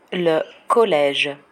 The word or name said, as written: le college